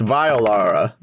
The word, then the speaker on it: Violara: And another one appears and is also going to attack Violara. – Oh! – There's so many! – Fuck. If you're going to kill me, at least pronounce my name correctly. It's Violara, like violin.